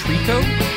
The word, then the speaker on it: Tricot: it's pronounced Trico. But it's spelt T-R-I-C-O-T, like Tricot, but Trico.